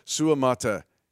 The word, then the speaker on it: Suamataia: So that fifth syllable, it's actually 5 syllables. Suamataia is the way he says it.